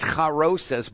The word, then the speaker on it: charoses: It's actually pronounced charoses,